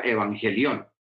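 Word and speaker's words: euangelion: Recordemos que la palabra Evangelio es una palabra griega y la palabra griega evangelio o evangelión, como se pronuncia en griego, se, pronuncia evangelión.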